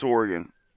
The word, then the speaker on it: Oregon: It's almost like kind of a third and short type take here, but I hate the way that Yankees or northern people pronounce Oregon. – It's not Oregon. – I mean, it's like nails on a chalkboard to me. – It's Oregon. It's Oregon State.